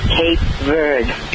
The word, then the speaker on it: Cape Verde: But when he mentions Cape Verde, it's pronounced Cape Verde. – It's just silent. I was wondering about that, because I've heard two pronunciations, so it's Cape Verde. It's pronounced. – He is silent.